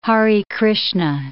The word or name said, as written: Hare Krishna